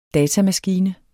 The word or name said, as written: datamaskine